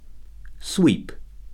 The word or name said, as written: sweep